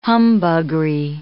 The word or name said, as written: humbuggery